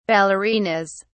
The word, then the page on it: ballerinas: British English